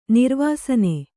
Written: ನಿರ್ವಾಸನ

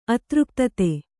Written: ಅತೃಪ್ತತೆ

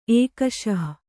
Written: ಎಕ್ಕಿಸು

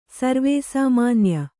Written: ಸರ್ವೇಸಾಮಾನ್ಯ

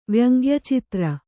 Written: ವ್ಯಂಗ್ಯಚಿತ್ರ

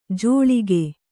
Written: ಜೋಳಿಕೆ

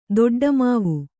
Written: ದೊಡ್ಡಮಾವು

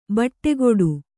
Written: ಬಟ್ಟೆಗೆಡು

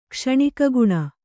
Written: ಕ್ಷಣಿಕಗುಣ

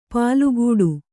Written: ಪಲ್ಕಡಿ

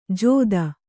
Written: ಜೋದ

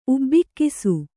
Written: ಉಬ್ಬಿಕ್ಕಿಸು

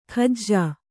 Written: ಖಜ್ಜಾ

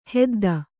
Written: ಹೊದ್ದು

